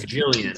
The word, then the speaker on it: Gillian: but is it Gillian or Gillian? – It's Gillian. – Gillian. – So she pronounces it correctly.